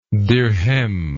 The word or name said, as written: dirhem